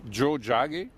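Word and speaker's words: Tiohtià:ke: Alors on dit Joe Jage qui veut dire Montréal. C'est en langue mohawk.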